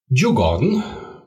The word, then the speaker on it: Djurgården: It's AIK, which is spelled A-I-K, Stockholm. Djurgården, which is spelled D-J-U-R, and then the garden.